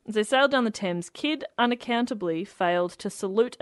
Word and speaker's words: Thames: It says, as the Adventure Galley sailed down the Thames.